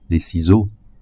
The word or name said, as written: ciseaux